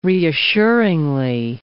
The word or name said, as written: reassuringly